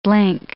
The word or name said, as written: blink